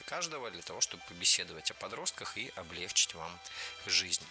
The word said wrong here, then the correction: облегчить: Не облегчить, а облегчить. Не облегчили, а облегчили. Не облегчит, а облегчит. В глаголе «облегчить» и во всех его формах ударение ставим всегда за корнем, а корень оставляем безударным.